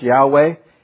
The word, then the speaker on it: Yahweh: It's translated the Lord, but the word there is J-H-V-H. We don't know how to really say it, but we put those vowels in. It sometimes is pronounced Yahweh, and it literally means I am.